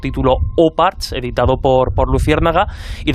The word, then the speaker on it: OOPArts: Un objeto que, puede ser sorpresivo, hay que decir que yo para, para este capítulo de los OPA o Uparts, pronunciado de forma más, más correcta,